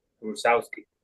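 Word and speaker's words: Rusowsky: Eh, se llama Rusowski, se escribe ruso W-S-K-Y, Rusowski.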